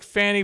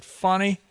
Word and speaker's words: Fani: Georgia judge ruled Friday today that Fulton County District Attorney Fani, looks like Fanny, but she pronounced it Fani, should not be disqualified from prosecuting the racketeering case against former President Donald Trump under one major condition.